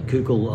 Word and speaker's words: Koukl: And as he finished his story of reality, Kukul, I'll spell it for you, is K-O-U-K-L, Gregory Kukul, K-O-U-K-L, in case you want to look it up.